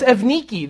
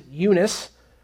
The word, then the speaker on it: Evniki: I know my pronunciation sounds different than it looks in the Bible. In English, you might pronounce it Eunice. But her name is Evniki in her time, so we'll call her that this morning.